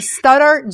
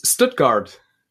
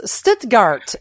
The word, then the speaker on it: Stuttgart: You live in Stuttgart, Germany. Am I saying that right? S- Stuttgart. – Not even close. Stuttgart.